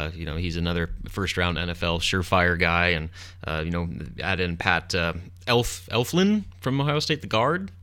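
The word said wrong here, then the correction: Elflein: Eflane, I believe it's pronounced.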